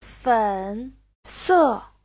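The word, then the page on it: fense: fen3se4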